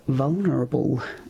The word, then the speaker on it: vulnerable: Thanks to Neil, who's texted in, a regular listener, saying to be aware of how you pronounce vulnerable, making sure you get the L in.